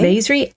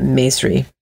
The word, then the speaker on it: Maesri: Maisri, M-A-E-S-R-I.